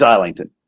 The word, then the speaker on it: Islington: It's Islington.